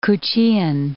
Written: Kuchean

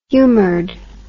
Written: humored

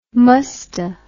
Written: musta

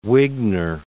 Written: Wigner

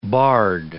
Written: bard

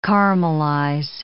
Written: caramelize

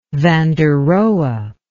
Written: van der rohe

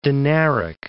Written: Dinaric Alps

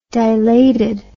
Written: dilatedness

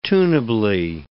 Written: tunably

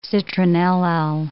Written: citronellal

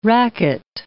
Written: racquet

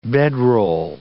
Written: bedroll

